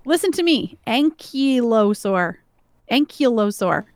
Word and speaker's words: ankylosaur: All right, you felt like an ankylosaur who might be in danger.